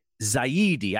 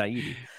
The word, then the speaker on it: Zaidi: Or Matthew on Giant Cocktails podcast, he pronounced it so much better than I do. Zaidi. I just want to say Zady, just because that's how it looks like, how it's supposed to say Zaidi.